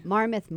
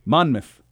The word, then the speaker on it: Monmouth: Marmouth Mama. – Monmouth.